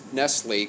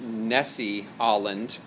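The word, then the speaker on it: Nestle-Aland: And I want to pronounce this Nestle, because that's what it looks like, but if I understand correctly, it's Nessie-Olland. So, like Nestle, so if you're writing it down, like Nestle Crunch Bar. Olland, A-L-A-N-D.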